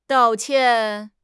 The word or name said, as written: dao4 qian4